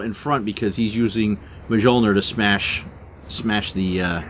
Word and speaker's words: Mjolnir: The J is pronounced like a Y, so it's Mjolnir.